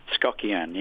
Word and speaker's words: Skokian: The band called, do I say it right, Skokian? – Skokian, yeah.